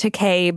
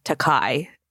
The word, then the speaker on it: Takei: It is pronounced Takei, but Bill has always said Takai.